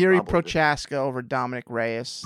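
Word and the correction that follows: Procházka: Prohaska. Get the name right if he's going to be the champ.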